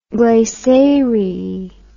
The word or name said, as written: glessariae